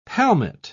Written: palmate

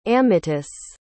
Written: amytis

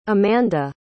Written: amanda

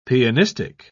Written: pianistic

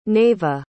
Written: naeva

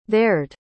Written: there'd

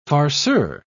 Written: farceur

